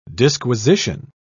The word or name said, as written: disquisition